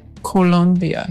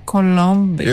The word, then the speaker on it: Colombia: Colombia. – It's very sexy, huh? Oh. Say it again. – Colombia.